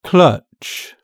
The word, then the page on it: clutch: British English